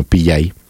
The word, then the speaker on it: Pillay: La sudafricana Navi Pillay, mil disculpas ahí la pronunciación, es doble L, vamos a dejarlo como Pillay,